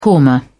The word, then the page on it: korma: British English